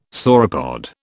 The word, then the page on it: sauropod: British English